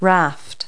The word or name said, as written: raft